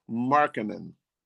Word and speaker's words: Markkanen: and that is Lauri Markkanen. And I probably pronounced his name wrong because he has two K's. M-A-R-K-K. – You pronounced it right.